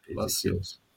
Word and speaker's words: Palacios: Ezequiel Palacios, spricht man den so aus? – Palacios.